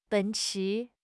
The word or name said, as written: 奔驰